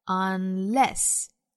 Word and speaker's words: unless: 后面这个词啊重读在后 unless 包含的是 a 的元音 a 就是小写的 e 的这个单元音啊 unless 前面这个词 worthless 包含的 less 是弱读音节，读成 less 或者 less。